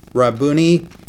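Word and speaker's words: Rabboni: She turned and said to him in Hebrew, "Rabuni," did I pronounce that right?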